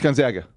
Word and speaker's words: Gonzaga: Did you say it correctly? – Gonzaga. – No, it's Gonzaga.